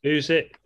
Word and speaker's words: Usyk: AJ versus Olesk? Is that pronounced it? Usk? – Alexander Usyk, yeah. – Usyk. He's foreign.